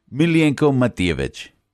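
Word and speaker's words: Miljenko Matijević: Miljenko Matijevic. Now, did I say that right? – You did perfect.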